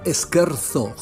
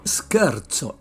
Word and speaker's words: scherzo: Pues se escribe scherzo, scherzo, pero no se pronuncia scherzo, jóvenes, que se recuerdan al anfibio correspondiente, sino scherzo, scherzo, en italiano, y significa broma, broma, bambino.